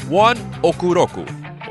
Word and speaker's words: ONE OK ROCK: It's by the Japanese rock band, which is properly pronounced One Okuroku, or spelled out One Ok Rock.